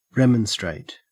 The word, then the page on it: remonstrate: Australian English